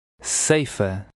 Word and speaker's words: ceifa: The EI is pronounced as in say. Try to read number 11. – Ceifa.